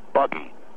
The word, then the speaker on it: Buggy: My name is Buggy. Okay, you can pronounce it Boogie if you want. B-U-G-G-Y, all right?